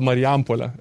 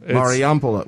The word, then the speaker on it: Marijampolė: I apologize if I'm pronouncing this wrong, but you grew up in Marijampolė, Lithuania. Is that correct? Or is that your? – No, I was born there. It's called Marijampolė.